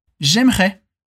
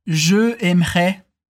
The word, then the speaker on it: j'aimerais: Aqui a gente junta o je com aimerais, para dizer j'aimerais. Repete comigo. J'aimerais. E aqui cuidado, hein? Nada de pronunciar je aimerais. A gente tem que juntar, falar mais rápido e fica j'aimerais.